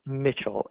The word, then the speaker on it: Mitchell: Mitchell, MITCHELL.